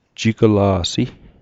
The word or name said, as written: jigla'si